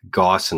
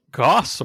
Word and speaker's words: Gauss: How do you pronounce Gauss rifle? For me, it rhymes with mouse rifle. – Yeah, Gauss.